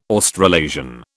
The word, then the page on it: Australasian: British English